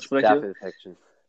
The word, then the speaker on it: Staphylokokken: Staphylokokken, wenn ich das richtig ausspreche. Staphylokokken.